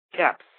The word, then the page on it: caps: American English